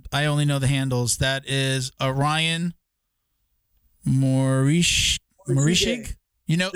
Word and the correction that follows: Morishige: How do you say his name? Ryan what? – Morishige. – Okay, good. Because I butchered it.